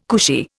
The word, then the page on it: cushy: Indian